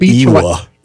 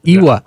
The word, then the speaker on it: Ewa: I would have said Eva. – Ewa. Yeah. I was going with the Ewa. – E-W-A.